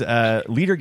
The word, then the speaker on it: Leder: Leader Games, by the way, is spelled LEDER, listener. – It's like Lederhosen.